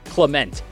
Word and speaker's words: Clement: Hudson Clement. And yes, that is how you pronounce it.